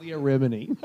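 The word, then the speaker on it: Leah Remini: So it's Leah Rimini. They're correcting us, pronouncing her name wrong.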